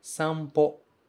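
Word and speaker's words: sampo (散歩): Nesse caso, essa palavra se pronuncia sampo. Sampo. Os dois kanji juntos se pronunciam sampo. O primeiro é san, o segundo é ho. Mas esse ho vai mudar por causa do san e vai virar po. Ele vai ganhar uma bolinha, um maru.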